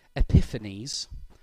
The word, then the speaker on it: Epiphanes: He gave himself the name Epiphanes, which sounds like the word epiphany.